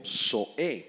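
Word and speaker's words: soe (ζωή): Subraya la palabra vida, proviene del griego SOE, con Z y acento en la E, soe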